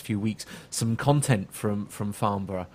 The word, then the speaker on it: Farnborough: Farnborough.